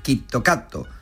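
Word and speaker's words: Kitokato (キットカット): En japonés se pronuncia Kitokato,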